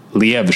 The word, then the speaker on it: Liev: He was on Stephen Colbert and he was saying that, most people who don't know like Hebrew words seem to put the emphasis on the first one, Liev. – That's really interesting. – But it's Liev.